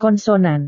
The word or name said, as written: konsonan